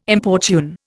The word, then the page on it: importune: Indian